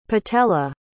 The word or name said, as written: patela